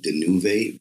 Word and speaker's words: Danuve: Danuva with the accent over the A. It's pronounced and said Danuve.